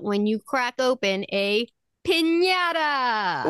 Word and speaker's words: piñata: And we all know that it's pronounced piñata.